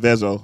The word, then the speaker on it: Vezo: Make sure you say his name right. Vezo.